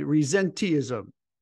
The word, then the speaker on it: Resenteeism: Presenteeism and Resenteeism. I pronounced that right, right, Chris? – Yep, perfectly.